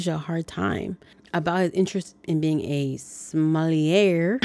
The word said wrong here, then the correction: sommelier: Oh my gosh, I totally butchered that. I'm gonna just have Skynet say like filling in every time I'm saying it. So that's who's gonna be talking because apparently I cannot get this word. Sommelier.